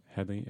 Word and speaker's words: Headey: Headey, yeah. – Headey. – She's Mama. Headey. – Yeah, I had been saying it wrong, too. For some reason, I saw an L in there.